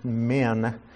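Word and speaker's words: men (מן): Kind of interesting, in chapter 12 and verse 1, the Hebrew word men, and that's pronounced men, it's the word from in Hebrew, is used with his country, his relatives, his father's house in Genesis 12:1.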